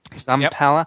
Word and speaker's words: Zampella: Am I saying his name right? Zampella. Yep. Jason West has departed Respawn, the studio he co-founded after sacking from Infinity Ward.